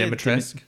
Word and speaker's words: Dimitrescu: Lady, how do you say Dimitrescu? – Dimitrescu.